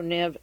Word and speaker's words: Neves: T-A-N-C-R-E-D-O, Tancredo Neve, N-E-V-E-S, the S is silent.